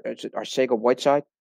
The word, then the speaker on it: Arcega-Whiteside: He got his pick, we know our our our, Arcega-Whiteside. That's the correct pronunciation.